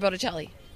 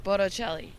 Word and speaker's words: Bocelli: Wait, I think you said his name wrong. Wait, how do you say it? – Andrea Bocelli. – How are you saying the last name? – Bocelli. – Bocelli? It's that? – Yeah.